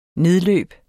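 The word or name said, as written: NEDLØB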